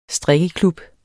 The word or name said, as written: strikkeklub